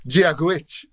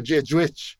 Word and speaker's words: Dia Duit: Dia Duit. The right pronunciation is Giaguich or Giajuich.